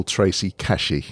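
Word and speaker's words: Kashi: Well, it's a pleasure to have on the show, on the podcast today, the wonderful Tracy Cashy.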